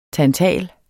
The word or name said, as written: tantal